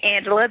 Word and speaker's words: Angelia: I am actually, you can find me on Facebook under Coach, and they're calling me Angela. That's right, but it's spelled like Angelia, A-N-G-E-L-I-A, Coach, A-N-G-E-L-I-A, on Facebook.